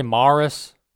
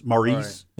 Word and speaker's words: Maris: In 1998, what Major League Baseball player broke the single season home run record previously held by Roger Maris? Maybe butchering that last name, but... – No, you got it. Roger Maris.